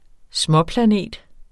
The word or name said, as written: småplanet